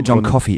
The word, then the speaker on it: John Coffey: John Coffey im Übrigen. – John Coffey. Sorry. Ich habe mir gestern gesagt, das wird John Coffin ausgesprochen. – Auf keinen Fall, John Coffey.